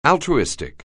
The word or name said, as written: altruistic